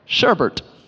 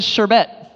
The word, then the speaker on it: sherbet: We put an extra R at the end when we say it. I'd love some sherbet. But I've been saying it wrong my whole life. The way you're supposed to say it is sherbet or sherbet.